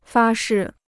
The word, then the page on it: 发誓: fā shì